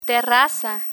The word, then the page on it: terraza: spanish